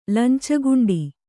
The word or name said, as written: ಲಂಚಗುಂಡಿ